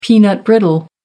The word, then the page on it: peanut brittle: American English